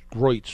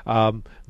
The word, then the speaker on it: Griot: which I have pronounced Groitz for the longest time, I think it's Griot,